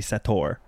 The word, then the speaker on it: Sator: It's spelled S-A-T-O-R, and I wanted it to be Sator. – Yeah. – But it's Sator. And they make that very clear.